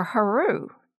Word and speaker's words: Heru: However, in the Egyptian language, his name is written capital H, lowercase r, and pronounced Her, H-E-R, or Heru, H-E-R-U, the ancient sky deity or sun deity of Egypt.